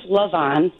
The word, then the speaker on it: Levon: Well, my name's Levon. – Levon. – Way to go, Paul. – Come on, Paul. – My bad. It's spelled like Levon, just for the record, which is an Elton John song.